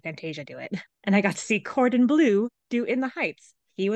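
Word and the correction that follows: Corbin Bleu: Can you say his name again? – Corbin Blue. – Oh, I thought you said Cordon Bleu, like Yeah. – with a D, and I was like, please keep saying this.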